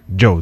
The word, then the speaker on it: DJO: Ahí estaba Joe. No es DJ O, sino Joe. – Exacto. Se escribe DJO, pero se pronuncia Joe.